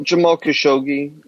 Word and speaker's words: Jamal Khashoggi: Jamal Khashoggi.